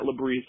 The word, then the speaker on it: Lebris: My website, all you have to do is search Matt Labrice, and that is spelled M-A-T-T, last name L-E-B-R-I-S.